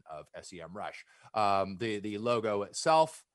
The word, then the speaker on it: SEMrush: Their logo now starts with a capital S, lowercase em, and then rush, and an actual audio going, the way to pronounce this is SEMrush.